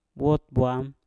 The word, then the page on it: wot bwam: mpur